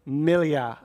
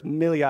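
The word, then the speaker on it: Millais: Could you get that other photo up, or I guess painting, by a painter here, by Sir John Everett Millia. I think that's, I was corrected after the service. Millia, I think, is how you say that.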